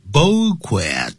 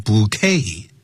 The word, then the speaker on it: bouquet: We wish youA pleasant, P-L-E-A-S-A-N-T, a pleasant trip. 好，所以这边代表祝福，祝福她生日快乐。And gave her a beautiful bouquet. 这个可不要念成 bouquet, 因为它是法文，变成了英文，念成 bouquet, T 不发音，叫一束，美丽的一束花。Gave her a beautiful bouquet of flowers.